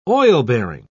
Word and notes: oil-bearing: British English

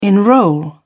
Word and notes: enrol: British English

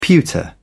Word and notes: pewter: British English

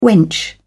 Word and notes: wench: British English

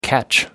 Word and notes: catch up: American English

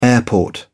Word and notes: airport: British English

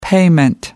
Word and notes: payment: American English

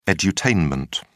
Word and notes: edutainment: British English

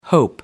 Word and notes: hope: American English

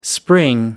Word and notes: spring: American English

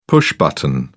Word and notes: push button: British English

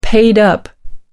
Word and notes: paid up: American English

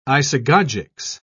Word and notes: isagogics: British English

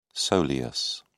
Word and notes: soleus: British English

